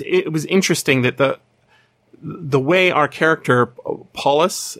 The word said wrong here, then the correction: Paulinus: is that how we're pronouncing his name? – I was saying Paulinus. – Paulinus, yeah, that sounds right. – I think there's an I in there.